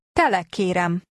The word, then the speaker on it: kérem: Tele kérem. Kérem. M. Kér, M. Tele. Tele kérem. – Note the ending on this word meaning I ask for.